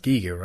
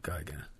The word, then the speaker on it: Giger: It's pronounced Giger, right? Not Geiger. – Is it Geiger? – I believe it's pronounced Giger anyway.